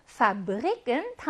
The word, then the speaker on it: Fabrik: 파브릭은 단어 중간에 있고 자음 앞에 있어요. 하지만 예외어였어요. 공장이라는 의미를 지닌 이 단어는 여기 있는 배가 ㅂ 발음이 나요.